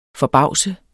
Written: forbavse

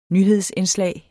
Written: nyhedsindslag

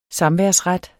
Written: samværsret

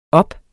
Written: op

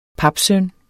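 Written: papsøn

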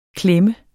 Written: klemte

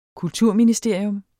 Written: kulturministerium

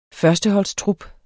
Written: førsteholdstrup

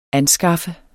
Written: anskaffe

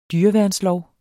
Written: dyreværnslov

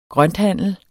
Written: grønthandler

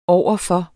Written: overfor

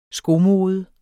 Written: skomode

